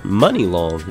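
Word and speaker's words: Muni Long: And for the hip hop and R&B concert, we will have rapper No Cap, Rob 49, and singer Money Long. Is how you pronounce it, correct? – Yes. – You know it's spelled M U N I.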